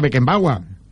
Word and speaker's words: Beckenbauer: Beckenbauer, en Alemania se pronuncia Beckenbauer.